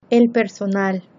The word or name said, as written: el personal